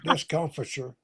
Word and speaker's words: discomfiture: Did I say that right? Discomfiture. And sorry. – Discomfiture. – Oh, discomfiture. And